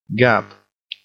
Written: gab

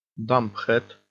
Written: dumb-head

